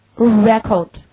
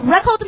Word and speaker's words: record: 你最重的音落在哪？第一个读 re，对不对？而第二个，刚才我们说，如果要作为另外一种读音，叫 record。那么它的重音落在第几音节？第二音节。